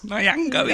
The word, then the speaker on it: Nyong'o: Winston Duke and Lupita Nyong'o. Close? – Nyong'o, he said.